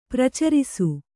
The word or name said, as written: ಪರಿಚಾರಿಸು